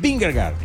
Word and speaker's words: Vingegaard: Usted me ayuda a pronunciar este corredor, este ciclista, el Jonas Vingegaard.